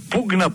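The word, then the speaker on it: pugna: Η λέξη πυγμή, ο πήκτης, το επίρρημα πήξ με ύψιλον, πυγ, πήκτης με ύψιλον, προσφέρεται στα λατινικά ή στη λέξη πουγ, διότι το ύψιλον προφέρεται ως ου στα ξένα, πουγνα πουγνε είναι η μάχη στα λατινικά κατά την οποία γίνεται χρήση γρόνθων, δηλαδή πουγνα πουγνε, πουνιά, η μπουνιά δηλαδή.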